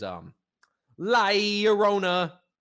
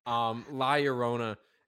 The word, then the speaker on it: La Llorona: Well, I can pronounce it because the proper way to say it is La Llorona. – Wow. – Yeah. So, La Llorona.